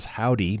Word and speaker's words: Hovvdy: Still to come, we've got a duo out of Austin, Texas, who calls themselves Howdy, but it's spelled H-O-V-V-D-Y.